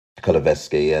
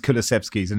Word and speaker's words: Kulusevski: Kane and him have gelled really well with Kulusevski, and I haven't said that right, have I? – We'll edit in one of Kulusevskis, and no one will notice. – Kulusevskis, yeah. Kulusevski.